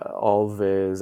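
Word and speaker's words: Alvvays: Alves? – It's like, all the word always, but spelled with two V's instead of a W. – Like a vampire would say it. – So yeah, Alves.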